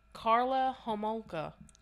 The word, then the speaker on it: Homolka: Okay, so this one probably gonna say it wrong. Karla Homolka. – You said it right.